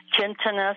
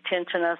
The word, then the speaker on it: tinnitus: Tinnitus, some say tinnitus, some say tinnitus. – No, actually it's either It's actually tinnitus.